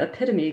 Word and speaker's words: epitome: When I expressed disbelief at the epitome correction, he furnished in an instant an unforgettable mnemonic device. It rhymes with, You gotta be kidding me.